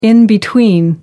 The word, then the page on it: in-between: American English